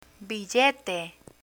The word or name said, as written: billete